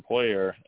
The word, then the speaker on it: Poirier: So the latest fight, it looks like, for Dustin Poirier is, if I pronounce that right, or Poirier. – Oh, you did.